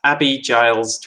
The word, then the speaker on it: Abi Giles: It's at Abi Giles 21. That's spelled A-B-I-G-I-L-E-S 21.